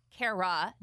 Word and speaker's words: Cara: My name is C-A-R-A. – I knew that. – And it is pronounced Kara, not Kara. – C-A-R-A-H.